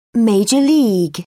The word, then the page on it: minor league: American English